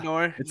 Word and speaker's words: Norge: Brian did spell it wrong. – Yeah. – Brian. You did spell it wrong. It's Norge. It's N-O-R-G-E. Norge Vera.